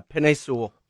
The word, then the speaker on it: Penei Sewell: So while he's lined up against Sewell, is that how you say it? – Yeah, Penei Sewell.